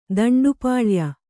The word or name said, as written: ದಂಡುಪಾಳ್ಯ